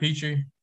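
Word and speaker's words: Pitre: Is that how you pronounce it? – Jalen Petrie.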